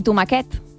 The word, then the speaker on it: tomaquet: Que és com tomàquet i tomaquet. El diminutiu de tomàquet. – És veritat. – S'escriu igual que tomàquet, però es pronuncia diferent.